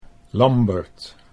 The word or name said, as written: Lambert